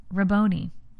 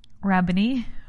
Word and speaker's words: Rabboni: She turned toward him and cried out in Aramaic, Rab- Rabboni. Rabboni, which means teacher. I can't I can't rhyme with macaroni, right?